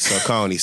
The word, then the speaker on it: Saucony: So write it phonetically. So like Saucony. So S-A-W, Coney.